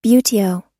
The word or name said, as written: buteo